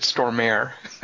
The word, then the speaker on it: Stormare: Peter Stormer Arms. Peter Sturms. Strimmers. – I like that you said Stormare.